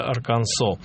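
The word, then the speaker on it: Arkansas: и он действительно произносится Арканзо.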